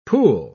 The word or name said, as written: pool